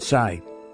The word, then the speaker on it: Tsai: President Tsai.